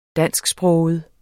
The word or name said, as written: dansksproget